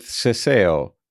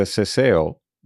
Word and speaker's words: seseo: Next, we'll hear Paulina pronounce the same words with seseo, which is spelled S E S E O.